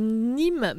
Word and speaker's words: Nismes: Euh, j'ai vu que c'est tourné dans deux endroits, dans un endroit qui s'appelle Nîmes, mais pas Nîmes comme en France, ça, s'écrit Nisme, N-I-S-M-E-S. – Mm-hmm. – Mais ça a la même prononciation, c'est drôle.